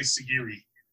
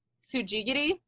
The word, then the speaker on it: Sujigiri: Well, I mean, if the U was here, I don't know what the U would do to it, but as it's written on the label, I would say Sigiri. – Okay. Those are all like fairly close. not quite, but it's pronounced Sujigiri, which like. – Sujigiri, no doubt. It's like no diggity.